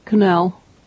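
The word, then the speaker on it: Connell: Cannell.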